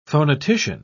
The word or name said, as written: phonetician